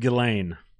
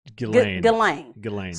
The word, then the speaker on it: Ghislaine: Ghislaine. – So they had to go through G H I S L A I N E to spell Ghislaine? – Apparently.